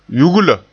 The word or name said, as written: yugla